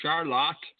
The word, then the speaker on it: Charlotte: I am in Charlotte. You got to pronounce it that way because that's the way they do here in Michigan.